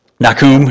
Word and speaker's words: Nahum: That's a horrible pronunciation if you're speaking Hebrew, but we're not, so we're using the English today. Yeah. Nahum in the Hebrew, but we're going to, what's that? Pretty close. Yes. Probably should have a little more throatiness to it in the pronunciation, but you might think of Nahum as Jonah 2.0.